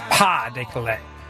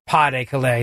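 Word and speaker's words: Pas-de-Calais: But thank you to Scott Martin for stepping up, at least, and reminding me that the S is silent in pas de Calais.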